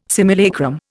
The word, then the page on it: simulacrum: Indian